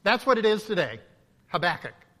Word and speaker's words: Habakkuk: And so just for sake of choosing one, I grew up with the name Habakkuk. And so emphasis on the back, and that's how I'm going to pronounce it.